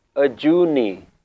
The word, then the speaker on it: Ajuni: So here the pronunciation is Ajuni. In Bani, in Shabads, it will be pronounced Ajuni.